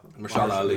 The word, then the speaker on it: Mahershala Ali: Mahershala Ali.